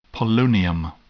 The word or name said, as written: polonium